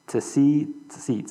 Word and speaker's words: tsiyt: T-S-I-Y-T. The pronunciation is a little difficult. It's basically tseet. So the word is like has a soft T in front of the word see. So tseet. So T-S-E-E, T-S-E-E with a T-H at the end.